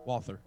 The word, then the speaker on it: Walther: How do you properly say it? Walther? – Walther. – Walther. – Walther PPQ. – Yeah. – As it is pronounced in all James Bond movies.